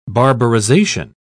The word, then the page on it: barbarization: British English